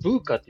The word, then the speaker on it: VUCA: ブーカ、あ、これブーカって読むブーカって言いがちですね、割と。